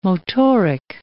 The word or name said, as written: motoric